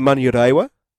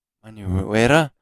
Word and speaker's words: Manurewa: Then we've got Ted from Mamarera. Have I said that correctly? – Well, that's how it's written. It could be Manurewa. – Manurewa.